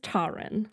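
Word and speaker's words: Taran: Taryn, let me check the pronunciation guide. – The book does have a pronunciation, which was some help. This says Taryn. – Taryn. Okay, Taryn. We'll just say Taryn then.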